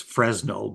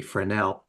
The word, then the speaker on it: Fresnel: And so the revival of the wave theory of light was cemented by the gifted French physicist Augustin Jean Fresnel. Now, we pronounce that in here as Fresnel, but it's really Fresnel, whose remarkable research in optics led to the almost unanimous acceptance of the wave theory of light.